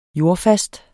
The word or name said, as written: jordfast